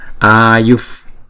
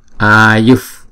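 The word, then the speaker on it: āyuḥ: Ngay chỗ chữ h có dấu nặng này là một visarga, nhưng mà theo sau đó là chữ p phở, cho nên chúng ta sẽ chuyển cái visarga này thành một âm là âm f, cho nên chúng ta đọc là ayuf, ayuf palani, không? Để chúng ta đọc cho nó trơn tru trôi chảy.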